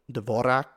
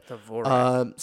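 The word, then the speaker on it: Dvořák: And media, like, it's used frequently enough, but I'm gonna butcher the name, but you know, Dvorak, I said it wrong, but Dvorak.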